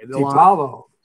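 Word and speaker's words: DePaola: How do you say his last name anyway? – DiPaolo. D. He's got a D-E.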